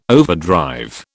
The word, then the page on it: overdrive: British English